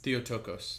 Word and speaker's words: Theotokos: And it translates more accurately from Greek as, I always say it wrong, Theotokos? – Theotokos.